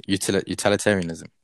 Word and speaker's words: utilitarianism: And that's called, this like principle is called utilitarianism.